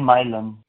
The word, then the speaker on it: Milan: Aquí, lo, Aquí lo pronuncian Milan, pero...